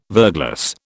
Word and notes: verglas: British English

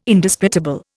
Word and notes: Indisputable: Indian

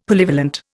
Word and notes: Polyvalent: Indian

